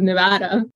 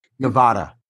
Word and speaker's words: Nevada: I do want to fight you on the pronunciation of Nevada. – Oh, shit. Nevada. – Nevada. – Oh, But there's no option to say it another way? – No, everybody here cringes when we hear Nevada. – Why do so many people say it incorrectly, do you think? – Because nobody corrects them.